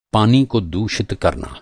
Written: दूषित

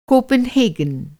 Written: कॉपनहेगन

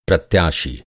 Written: प्रत्याशी

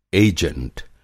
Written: एजेंट

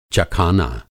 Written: चखाना

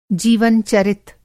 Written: जीवन-चरित

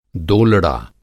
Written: दोलड़ा

